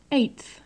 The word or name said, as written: eighth